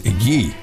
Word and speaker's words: Ге: Нет, дело в том, что э, французский его фамилия писалась Гей. Ах, вот. А читается Г.